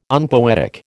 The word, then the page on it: unpoetic: American English